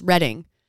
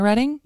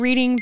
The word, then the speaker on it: Reading: I was in Redding. It's pronounced Redding. – Oh, like in California, Redding? – Yes, but it's spelt Reading. – Well, that's bull. – I remember before I left Kevin, our buddy Kevin Flowers, he was like, whatever, you should just keep pronouncing it Reading. Have everyone correct you.